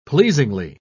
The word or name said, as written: pleasingly